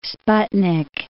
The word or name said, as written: sputnik